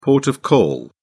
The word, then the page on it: port of call: British English